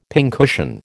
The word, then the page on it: pincushion: American English